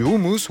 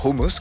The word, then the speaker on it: houmous: C'est le cas du houmous, ou hummus, comme il faut le prononcer.